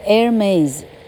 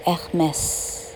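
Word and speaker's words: Hermès: 爱马仕的美语发音叫做 Hermes 法文发音叫做 Hermes，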